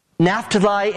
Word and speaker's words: Naphtali: Naphtali and Zebulun, that's the way they're pronounced in the Old Testament.